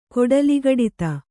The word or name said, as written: ಕೊಡಲಿಗಡಿತ